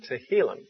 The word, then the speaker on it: Tehillim: I'll spell it for you if you want to write that down. The Hebrew word Tehillim, T-E-H-I-L-L-I-M, Tehillim.